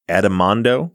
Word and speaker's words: Addimando: So we are talking about the case of Nikki Adamondo.